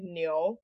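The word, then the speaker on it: niu (牛): So ox is pronounced like niu. – Niu. – And so it sounds like new.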